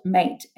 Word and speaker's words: mate: It's actually spelled mate, M-A-T-E, but it's pronounced mate.